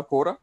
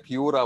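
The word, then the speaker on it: Quora: هي النطق الانجليزي الصحيح بتاعها كوره آه مش كيوره ولا قيوره ولا اي يعني آه تلفظات اخرى يعني آه، ولكن هي تنطق آه، كوره ايوه حلو يعني.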